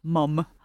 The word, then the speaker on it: mom: it's pronounced mum, like M-U-M, but it's still spelled mom, M-O-M.